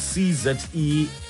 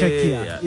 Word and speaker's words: Czechia: It's CZECHIA. – Czechia. – Czechia. – Or yeah. – Czechia. Either or.